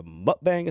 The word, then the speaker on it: mukbang: It's a mukbang, is that pronouncing it right? – Yeah.